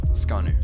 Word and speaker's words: Scanu: How do you say your last name? – Scanu.